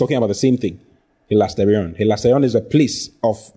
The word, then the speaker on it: hilasterion: Then the next word is hilasterion. Hilasterion. H-I, hilasterion is spelled H-I-L-A-S. T-E-R-I-O-N. H-I-L-A-S-T-E-R-I-O-N. Hilasterion.